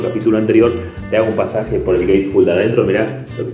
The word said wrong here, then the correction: gatefold: Gateful, dije, ¿no? O sea, quise decir Gatefold, pero dije, pronuncié Gateful. Sería el, portal del tonto.